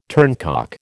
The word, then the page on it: turncock: American English